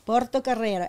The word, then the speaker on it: Portocarrero: Portocarrero, absolutely, yes.